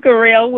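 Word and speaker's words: Garrelle: I'm Gerald Wilson. And okay. That's G-A-R-R-E-L-L-E Wilson.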